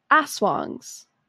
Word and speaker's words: aswang: I live in Georgia, but my mom is from the Philippines, and I grew up hearing about and watching movies about aswangs. – Sorry. Aswangs? – And then in parentheses, okay, it says, pronounced like aswang.